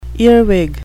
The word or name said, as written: earwig